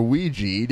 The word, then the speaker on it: Ouija: Or Ouija, depending on how you want to say it. – Ouija. – I've heard it's correct to pronounce it both ways. I just go based on how it's spelled. It seems like it should be Ouija.